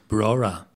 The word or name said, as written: Brora